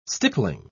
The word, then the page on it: stippling: British English